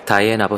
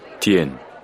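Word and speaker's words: Diane: 다이앤 아버스, 좋네요. – 딘이 움찔하더니 조그만 이목구비가 그 조그만 얼굴 한가운데로 매듭이라도 짓듯이 모여들었다. – 디엔이에요. – 네? – 디엔. 저분 이름은 디엔이라고 발음한다고요.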